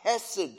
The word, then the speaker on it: chesed: You could spell it like this, C-H-E-S-E-D. It's kind of a hard sound at the beginning, chesed.